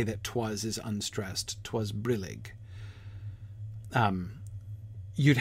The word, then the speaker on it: brillig: I think brillig is clearly with the stress on the first syllable.